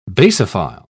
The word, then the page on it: basophile: British English